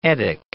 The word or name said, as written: etic